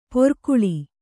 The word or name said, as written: ಪೋರ್ಕುಳಿ